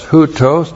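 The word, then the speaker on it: Houtos (οὕτως): Houtos looks like this in the Greek. H-O-U-T long O-S. Houtos. Houtos means in this manner, or thus, or so. And then we have sperma. S-P-E-R-M-A.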